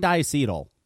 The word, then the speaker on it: diacetyl: It's fucking diacetyl.